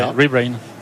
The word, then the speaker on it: Rebrain: Est-ce que c'est comme ça qu'on prononce ? Rebrain ? – Tout à fait, Rebrain.